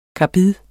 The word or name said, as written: KARBID